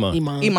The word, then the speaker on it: Iman: Because her and Iman, how do I say his name? – Iman, yeah.